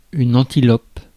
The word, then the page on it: antilope: France; [ɑ̃.ti.lɔp]